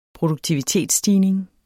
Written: produktivitetsstigning

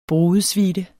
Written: brudesuite

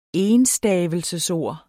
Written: ENSTAVELSESORD

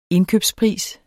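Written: indkøbspris

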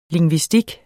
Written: lingvistik

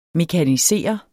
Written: mekanisere

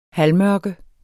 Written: halvmørke

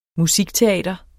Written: musikteater

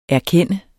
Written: erkende

